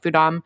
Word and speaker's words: Fudam: And then when I learned about the farm that this comes from, the alliance that it comes from, which is called Fudam, is that how they pronounce it? Fudam, F-U-D-A-M. – Yes.